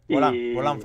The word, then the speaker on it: Volant: Sí, sí, que está, y que como recordamos aquí también está eh, compaginando la Copa Suzuki con el volante Fapa. – Volán, Fapa. – Por el volán Fapa, perdón.